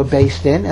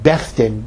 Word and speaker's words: Beth Din: That the parties agree to go to a Beis Din, and they pick the Beis Din. Now, the RCA Beis Din is called Beis Din of America, and they pronounce it Beth Din, B-E-T-H. D-I-N of America.